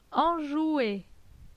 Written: Enjoue

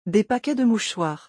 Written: Des paquets de mouchoirs

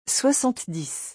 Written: 70

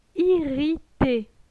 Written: Irrite

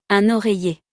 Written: Un oreiller

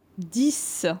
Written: 10

